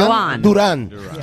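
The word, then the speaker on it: Jhon Durán: It's a soft J, so it's Joanne. – Soft J, Joan Duran.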